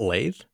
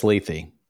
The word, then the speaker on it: Lethe: Isn't it Lethe? – Nope, it's Lethe.